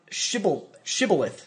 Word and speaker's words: shibboleth: And trying to remember the word shibboleth, sorry, which I continue to mispronounce by adding an N.